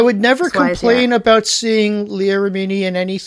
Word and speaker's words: Leah Remini: Leah Remini or Leah Remini? I don't know how to pronounce Leah Remini.